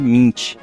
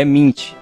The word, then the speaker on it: Mint: Uma outra coisa que ia comentar é sobre a pronúncia de mint, que é mint e não mint.